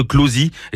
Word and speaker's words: Clozee: sous le nom de Closie, et ça s'écrit C-L-O-Z-E-E.